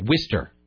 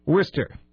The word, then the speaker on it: Worcester: W-I-S-T-E-R. – Okay, Worcester. – Worcester.